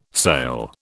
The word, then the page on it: sail: British English